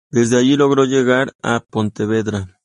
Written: llegar

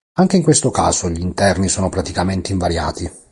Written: praticamente

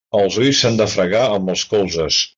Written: colzes

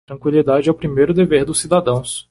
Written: dever

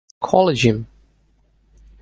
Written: collegium

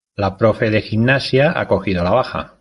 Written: cogido

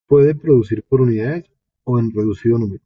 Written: reducido

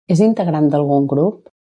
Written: integrant

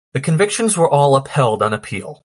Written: convictions

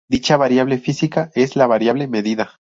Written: medida

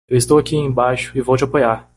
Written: apoiar

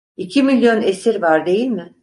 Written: esir

